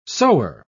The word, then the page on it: sower: British English